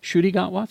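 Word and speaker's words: Ncuti Gatwa: Today, the BBC confirmed that Doctor Who's new season, starring Kuti Gatwa, is that how you say it? – The 15th Shuti Doctor. Gatwa. – Shuti Gatwa, thank you.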